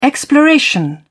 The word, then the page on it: exploration: British English